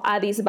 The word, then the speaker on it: Addis: En Facebook me encuentras como Adis Barrios.